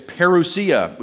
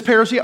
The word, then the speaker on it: parousia: It's parousia, which sounds kind of like a strange article of clothing or something. This parousia, I only wear this when I don't care how I look.